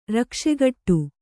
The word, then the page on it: ರಕ್ಷೆಗಟ್ಟು: Kannada